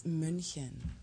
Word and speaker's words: München: You can say Munich all you want. It's München. – Wait. Say that again. – München. – Say it loud. – München. – Say it proud. – München.